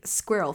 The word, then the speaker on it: squirrel: And now I guess we're going to be squirrel theme. Or like, as North Americans rodent. Like to say, squirrel. No, it's squirrel. It's squirrel. There are two syllables. Squirrel, not squirrel.